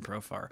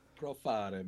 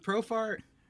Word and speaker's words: Profar: Profare, man. Profare. That's how I always say his name. I don't know why I add that to it. He's from Curacao, man. He's not from Italy. – I don't know why I do that. I still don't know why I do that. I know how to pronounce his name.